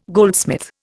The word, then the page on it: goldsmith: Indian